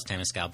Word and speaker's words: Temescal: I always tell people it rhymes with tennis gal. So if you want to know how to say it, just think Temescal.